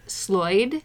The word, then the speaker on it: Sloyd: but the concept is Sloyd. That's the American pronunciation. I've seen the American spelling as S-L-O-Y-D, which is handcrafts, if you will.